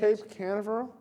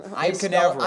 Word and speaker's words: Cape Canaveral: So on Sunday, the 12th in Cape Corral. – Cape Corral? – How do you say it? – Cape Canaveral? Hang on. – Cape Canaveral. – I butchered it.